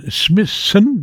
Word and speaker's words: Smithson: En Smithson, ja, zo zeg ik dat goed.